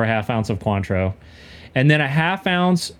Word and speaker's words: Cointreau: Cointreau, is that right? – Cointreau. – Cointreau? – Cointreau.